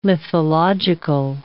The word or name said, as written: lithological